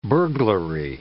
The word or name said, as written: burglary